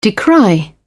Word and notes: decry: British English

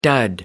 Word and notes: dud: American English

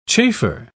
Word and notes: chafer: British English

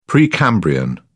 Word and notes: precambrian: British English

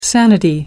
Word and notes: sanity: American English

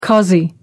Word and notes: cossie: American English

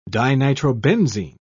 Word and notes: dinitrobenzene: British English

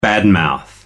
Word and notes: bad mouth: American English

